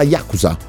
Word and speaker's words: Yakuza: Sarà Yakuza, è la classica pronuncia giapponese.